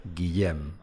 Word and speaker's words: Guillem: We're very privileged to be joined by William Balague. That's not pronounced right, is it? – No, it's not pronounced right. Try, try again. William Balague, yeah. Guillaume. Guillaume Balague.